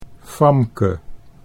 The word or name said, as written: Famke